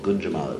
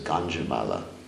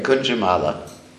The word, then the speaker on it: Gunjamala: But that's called Gunjamala. It represents Radharani. You have to be careful not to pronounce it as Ganjamala. Ganja is something else. But anyway, Gunjamala.